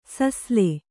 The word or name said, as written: ಸುಸಿಲು